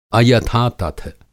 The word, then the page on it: अयथातथ: Hindi